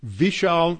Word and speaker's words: Vishal: Vishal Mangalwadi. His first name is spelled V as in Victor, V-I-S-H-A-L, and his last name is spelled M-A-N-G-A-L-W-A-D-I, Mangalwadi.